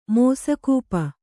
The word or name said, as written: ಮೋಸಕೂಪ